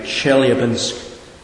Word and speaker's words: Chelyabinsk: Chelyabinsk in Russia.